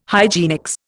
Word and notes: hygienics: Indian

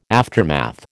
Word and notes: aftermath: American English

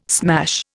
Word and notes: smash: Indian

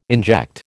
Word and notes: inject: American English

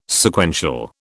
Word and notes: Sequential: British English

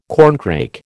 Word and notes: corncrake: American English

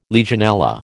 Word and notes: Legionella: American English

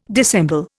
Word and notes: dissemble: Indian

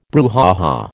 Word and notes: brouhaha: American English

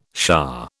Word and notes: shah: British English

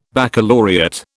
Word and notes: baccalaureate: British English

